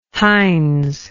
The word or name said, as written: hines